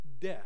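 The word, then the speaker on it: deh (δέ): It's the word that's spelled D-E, and it's pronounced just deh. Deh.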